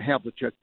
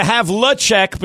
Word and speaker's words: Havlicek: Now, and I noticed you're doing the Havlicek because you're doing it like you're doing it really pronouncing every letter there. Most people say Havlicek, right?